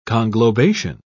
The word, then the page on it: conglobation: British English